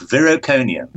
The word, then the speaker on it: Viriconium: And I think that's probably where we should really get rolling with it is I believe, I hope I pronounce it right, Viriconium? – That is correct, Viriconium.